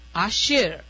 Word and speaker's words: Ashir: I think I got your name wrong the last time. Is it Ashra? – No, it's A-S-H-I-R. – Ashir. There you go.